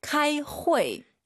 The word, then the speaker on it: 开会: 카이 후이. 네. – 开会. – UI 사이에 에 넣으세요. 회.